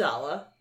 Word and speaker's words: Amidala: It's Amidala.